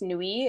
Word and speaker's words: NUI: I think it's pronounced Nui. It's NUI Organics.